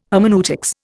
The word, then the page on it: hermeneutics: Indian